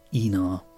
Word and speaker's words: Einar: A Scandinavian name, which Ray borrowed with a slight change of spelling for his character and short story, Uncle Enar, but spelled E-I-N-A-R. Now, you might pronounce it Einar, and I do sometimes, but I'm guessing that Scandinavian pronunciation is probably Enar.